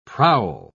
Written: prowl

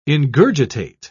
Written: ingurgitate

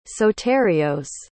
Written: soterios

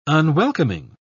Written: unwelcoming

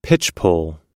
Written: pitchpole

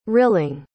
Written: rilling

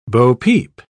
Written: bopeep

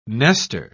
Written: Nester

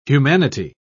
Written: humanity

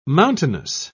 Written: mountainous

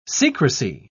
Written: secrecy

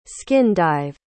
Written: skin-dive

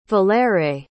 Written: valere

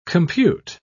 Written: compute

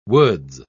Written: woods

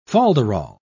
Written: folderol

